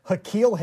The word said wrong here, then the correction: N'Keal: Harry? – Oh, N'Keal Harry. – N'Keal? N'Keal. – Yeah. – N'Keal in this case is spelled N-apostrophe K-E-A-L. N'Keal.